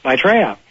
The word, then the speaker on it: Maitreya: Maitreya.